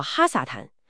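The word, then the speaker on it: 哈 撒 坦 (הַשָּׂטָן): 约错，那个定冠词是哈，连起来读作哈撒坦，而且撒坦在希伯来语里其实只是一个很普通的单词。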